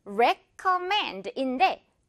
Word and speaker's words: recommend: 자, 이것도 역시 이 mend 부분에 가장 강하게 발음해주면 되고 이거는 recommend인데 recommend 약간 이렇게 실수도 많은 것 같아요. re가 아니라 에 발음입니다. 이거 re, recommend, mend 부분에 강하게요.